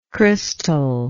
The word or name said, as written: crystallike